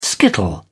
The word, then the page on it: skittle: British English